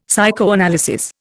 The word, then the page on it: psychoanalysis: Indian